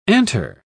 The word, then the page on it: antre: British English